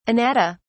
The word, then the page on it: anatta: British English